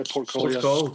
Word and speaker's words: Porthcawl: Porthcawl? Porthcawl? – Yeah, Porthcawl.